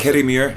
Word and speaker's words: Kirriemuir: Do you say Kirriemuir, or how do you pronounce Kirriemuir. – It? – Kirriemuir.